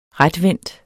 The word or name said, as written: retvendt